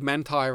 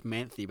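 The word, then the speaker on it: Manthey: Welcome to a mob called Manti Racing, M-A-N-T-H-E-Y. I always thought it was Manthi, but apparently it's Manti.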